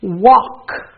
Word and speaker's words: walk: The next consonants are LK, where the L will be silent, as in talk. You can't hear the L. Walk.